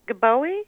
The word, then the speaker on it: Gbowee: Well, and then you look at someone like Lama Gbowee.